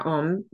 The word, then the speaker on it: Om: I expect there is various yoga schools of thought of on the mantra Om because Kundalini chanting, it is said ONG. So on, whereas others pronounce as AUM, which then turns into om.